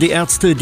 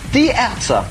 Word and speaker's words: Die Ärzte: Die Ärzte. Or pronounced properly, die Ärzte.